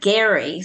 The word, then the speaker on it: Gerry: And then the third woman named Angelica Gerry, who was the great granddaughter of Thomas, we pronounce his name Gerry.